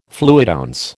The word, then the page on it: fluidounce: American English